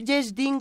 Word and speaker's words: Yesh Din: Se escribe Yeshdin como se, como se escucha.